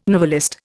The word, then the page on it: novelist: Indian